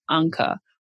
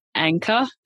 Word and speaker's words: Anker: So, just to call out some examples here for actively invested, there is a consumer tech accessory company called Anker. That's the German pronunciation. It's Anker to me.